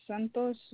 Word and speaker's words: Santos: Santos Martinez, SANTOS MARTINEC Verify a good callback number for you.